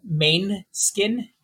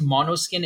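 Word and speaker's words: Måneskin: It's called Main Skin, ManeSkin. I think it's supposed to be pronounced Monoskin. It's Italian.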